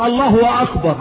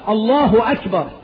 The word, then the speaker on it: الله أكبر: افواحة بيقولها غلط، يقولك الله هو اكبر، عملهم اثنين الله هو اكبر، لا طب ده الهاء مضمومة، هو اشبع هاء لفظ الجلالة بالضم فقلب الضم الى واو، مش الله هو أكبر، الله أكبر، الله هو.